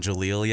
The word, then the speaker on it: Jeleel: It's for everybody out there who's not on Jaleel yet. – Yeah. – I'm gonna put everybody on. J-E-L-E-E-L exclamation point. You pronounce that Jaleel, yeah.